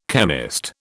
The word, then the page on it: chemist: British English